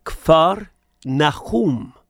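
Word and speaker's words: Kfar Nahum (כפר נחום): I did mention that our Lord went to the town of Capernaum, which in Hebrew is pronounced Kfar Nahum, which means the village of Nahum, Kfar Nahum.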